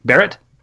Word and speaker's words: Barrett: Is it Barrett, or Barrett? How do we say her name anyway? Barrett.